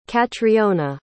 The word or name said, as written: catriona